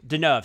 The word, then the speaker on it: Deneuve: And Catherine Devenue was actually considered for her as well at one point, but then they ended up going Catherine Deneuve? – Deneuve, sorry. God, I always say that wrong.